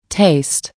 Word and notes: taste: American English

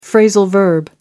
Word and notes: phrasal verb: American English

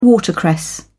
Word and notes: watercress: British English

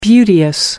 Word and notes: beauteous: American English

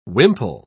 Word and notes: wimple: British English

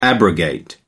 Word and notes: abrogate: American English

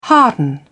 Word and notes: harden: British English

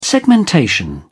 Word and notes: segmentation: British English

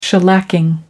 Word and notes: shellacking: American English

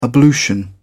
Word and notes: ablution: British English

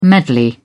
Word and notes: medley: British English

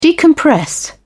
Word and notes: decompress: British English

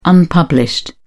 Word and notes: unpublished: British English

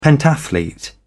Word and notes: pentathlete: British English

